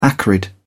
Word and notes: acrid: British English

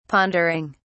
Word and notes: pondering: British English

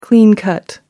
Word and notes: clean cut: American English

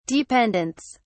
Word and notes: dependents: British English

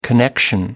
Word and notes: connection: American English

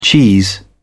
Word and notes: cheese: British English

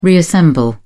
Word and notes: reassemble: British English

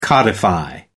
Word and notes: codify: American English

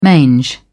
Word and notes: mange: British English

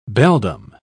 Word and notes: beldame: British English

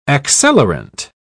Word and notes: accelerant: British English

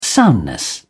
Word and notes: soundness: British English

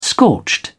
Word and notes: scorched: British English

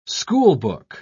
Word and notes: schoolbook: British English

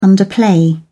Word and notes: underplay: British English

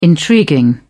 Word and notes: intriguing: British English

